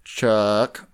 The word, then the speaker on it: Chuck: Chuck. – Chuck? That rhymes with truck.